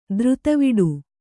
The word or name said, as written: ಧೀರತೆವಿಡಿ